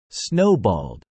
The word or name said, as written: snowballed